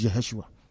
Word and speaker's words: Yeshua: His name is Yeshua. If you don't want to understand that, you can, I don't know what to say. There is no J in Hebrew pronunciation. It is Y.